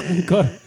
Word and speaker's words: Angkor: A-N-G-K-O-R. – Vale, pero no el esfuerzo. Ancor.